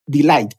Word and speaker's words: delight: hay una parte que se llama delayed, delight, perdón, siempre lo pronuncio mal, delay es otra cosa.